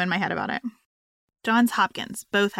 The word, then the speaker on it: Johns Hopkins: Did I say that right? Johns Hopkins. Johns Hopkins. Hopkins. Yeah. – Johns Hopkins.